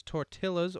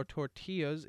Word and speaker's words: Tortillas: Number 7 was Tortillas or Tortillas, if you say it correctly.